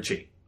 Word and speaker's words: chi: Because, like, your weapon is an extension of your ki. Is it ki or chi? – Chi. Chi. Dan's bet, chi.